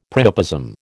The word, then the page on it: priapism: American English